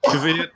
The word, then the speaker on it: Kvyat: I don't know if it's the correct pronunciation or not, but I just stick with Kvyat. – No, that's close enough.